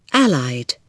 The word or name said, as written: allied